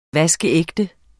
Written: vaskeægte

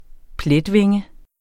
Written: pletvinge

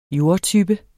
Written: jordtype

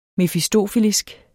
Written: mefistofelisk

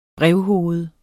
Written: brevhoved

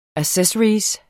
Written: ACCESSORY

